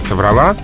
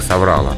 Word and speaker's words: соврала: Так соврала или соврала? – Ну, конечно же, соврала. А, но словари предупреждают о наличии такой ошибки, как соврала.